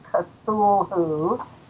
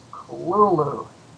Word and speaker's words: Cthulhu: It's not Cthulhu, as it looks on the page. It's Kululu. – So Kululu? – Be cool and say Kululu, as a clueless, Kululu.